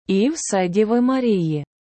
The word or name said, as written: і Вседіви Марії